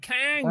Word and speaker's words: Kang: se pronuncia ah, Kang. – Ah. O sea, es Kang como Kang.